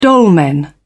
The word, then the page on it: dolmen: British English